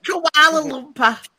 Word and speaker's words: Kuala Lumpur: Koala. – But, how do you say it? – Kuala Lumpur. You're so Australian.